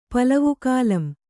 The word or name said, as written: ಪಲವುಕಾಲಂ